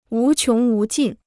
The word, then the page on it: 无穷无尽: wú qióng wú jìn